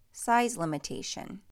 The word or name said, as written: Size limitation